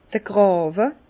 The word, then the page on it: d'r Gràwe: Bas-Rhin; Herrlisheim